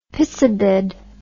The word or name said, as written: pissabed